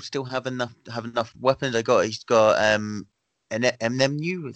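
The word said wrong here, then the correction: Enunwa: is that how you pronounce Inunua.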